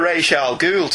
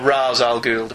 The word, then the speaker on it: Ra's al Ghul: And there's even a bit of Ra's al Ghul to it. Or Ra's al Ghul, depending on your pronunciation preference.